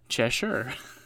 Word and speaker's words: Cheshire: Cheshire is pronounced Cheshire. – Yeah, I think I pronounced it that way.